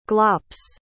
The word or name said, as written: glops